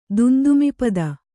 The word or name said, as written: ದುಂದುಮಿಪದ